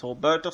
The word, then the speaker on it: Roberto: The first set of these qualifiers, which will take place over the next 2 events, are Bizuto Pugni-Sirota versus Roberto Satoshi de Souza. Note, he is Brazilian, so I'm pronouncing his first name with an H sound instead of an R.